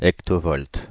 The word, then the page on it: hectovolt: France (Île-de-France); [ek.to.vɔlt]